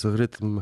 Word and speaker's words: Rytm: Firma nazywa się Elektron, a, a, e, a, a maszyna nazywa się Rytm, chociaż wymawiane po szwedzku to jest rytm chyba z tym R takim e, przeciągniętym i drżącym, natomiast zapis jest taki sam jak po polsku.